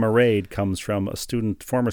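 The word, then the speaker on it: Mairéad: Her name is Maraid. It rhymes with parade.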